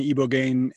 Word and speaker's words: ibogaine: It, it's really due to you know just the the impacts that psilocybin and, and is it ibogaine? Am I saying that correctly? Well, there's iboga and ibogaine, and then there's a whole bunch of other ones, just like in cannabis.